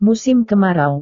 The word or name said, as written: musim kemarau